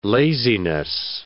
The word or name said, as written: laziness